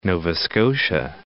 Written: Nova Scotia

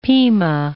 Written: Pima

